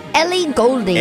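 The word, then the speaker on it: Ellie Goulding: Happy birthday, Miss Goulding. Did I say that right? – Ely Golding. – Ely Golding.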